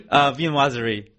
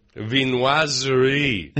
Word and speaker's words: Viennoiserie: Viennoiserie. – Viennoiserie.